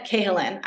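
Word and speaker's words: Cahalan: Is it Sarah Callahan? I don't know how it's yeah, pronounced. – It's Susanna Cahillan. I thought it was Callahan, then I had to record the audiobook, and it's Cahillan.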